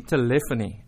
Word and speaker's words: telephony: I'm very anxious to find out what the word telephony means. – Well, good morning, first of all. Good morning. – It's actually telephony. – See, I'm even saying it wrong.